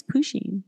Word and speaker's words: Pusheen: I think it's Pusheen. – Pusheen. – Pusheen. – Pusheen? How do you spell that? – P-U-S-H-E-E-N. Look it up.